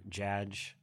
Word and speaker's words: Jaj: I would prefer Josh or Jaj or however, if you wrote those letters down, J-A-J, how do you say that?